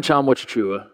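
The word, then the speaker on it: Tchamwa Tchatchoua: Jonathan Chamwa Chichua. Am I saying that correctly? – Yes.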